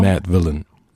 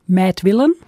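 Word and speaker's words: Madvillain: Ist das richtig ausgesprochen? – Mad Villain. – Mad Villain.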